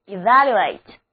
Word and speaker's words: evaluate: 他们这个出价的行为其实就是给拍卖的物品一个估价，所以 evaluate 就很形象地表示估价或者评价。字母 E 要读作短的 E，第二个字母 A 要读它的本音 A， evaluate。